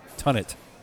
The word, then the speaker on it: Tonit: Tunit. – Spell that. – T-O-N-I-T. Tunit.